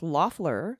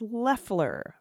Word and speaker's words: Loeffler: I thought it was pronounced Loeffler with the first syllable rhyming with off, but it's Leffler, rhymes with theft.